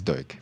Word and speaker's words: Duch: It's Duke. – Duke, sorry. Yeah, I knew I was going to do that at some point today. It is spelled D-U-C-H, so I had to train myself not to say Dutch anyway.